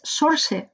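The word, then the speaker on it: Saoirse: Yo he aprendido a pronunciarlo y creo que es Sorce Ronan.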